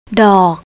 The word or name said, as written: dok